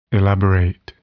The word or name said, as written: elaborate